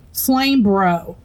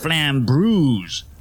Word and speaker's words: Flambrews: Hey, Flame Bro. – It was supposed to be pronounced Flambrews, like flambé.